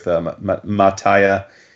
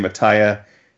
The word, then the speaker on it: Mattia: This is Abbott 1973 by Boom, written by Saladin Ahmed, artist Sammy Cavella, and colors by someone I wasn't familiar with, Mataya, or I guess it's Mataya because it would be Mataya, so I'm going to say Mataya Iacono, Vince, you're the Italian. M-A-T-T-I-A would be Mataya, I assume, and then I-A-C-O-N-O would be Iacono.